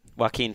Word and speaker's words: Joaquin: and Jacqueline. Is that how you pronounce it? – Joaquin Phoenix.